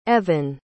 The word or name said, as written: evan